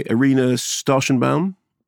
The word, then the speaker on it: Irina Starshenbaum: Screen Discovery, Irina, correct me if I'm pronouncing it correctly, Irina Starshenbaum? – I do believe that's correct.